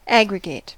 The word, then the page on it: aggregate: /ˈæ.ɡrɪ.ɡət/; [ˈæ.ɡrɪ.ɡət]; /ˈæ.ɡrɪ.ɡeɪt/; [ˈæ.ɡrɪ.ɡeɪt]